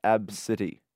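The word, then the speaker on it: ABCDE: It's not really Absidy, is it? It's A-B-C-D-E. – No, but it's pronounced Absidy.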